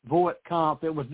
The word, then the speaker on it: Voight-Kampff: Spell the test name instead of Turing, T-U-R-I-N-G. – V-O-I-G-H-T-K-A-M-P-F-F. Voight Comp.